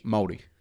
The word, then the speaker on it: Māori: I've read some books and I'm kind of like, you know, the Māori, is that correct how I said that? Do your best pronunciation of Māori. – Māori. – Okay, good. Are we doing it right?